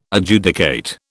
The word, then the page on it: Adjudicate: British English